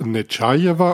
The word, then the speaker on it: Netschajewa: Und wir wissen jetzt, es heißt Netschajewa und wahrscheinlich wird das im Estnischen noch ein bisschen länger betont das A, aber die Betonung liegt auf dem A.